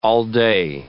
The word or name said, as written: all-day